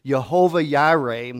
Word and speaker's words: Jehovah Jireh: Now, that's in the Hebrew, but in the Hebrew language, they don't pronounce the letter J as we do. And so if we looked at that in our language, it would be Jehovah Jireh, and it means the Lord will provide.